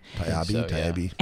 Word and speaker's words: Taibbi: Taibbi.